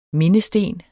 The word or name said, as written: mindesten